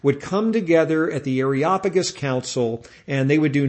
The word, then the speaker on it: Areopagus: At the time, Paul was in the Greek city of Athens, and he was in an area known as the Areopagus Council, sometimes pronounced the Areopagus Council, but more correct would be the Areopagus Council.